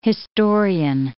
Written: historian

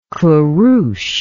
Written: qurush

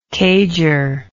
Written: cager

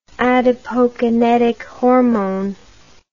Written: adipokinetic hormone

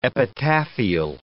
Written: epitaphial

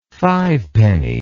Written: fivepenny

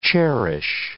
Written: cherish

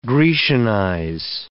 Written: grecianize

